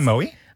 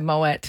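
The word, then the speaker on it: Moët: Did you say Moe? – I said Moet, because that's how you say it.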